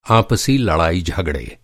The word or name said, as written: आपसी